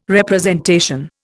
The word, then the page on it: representation: Indian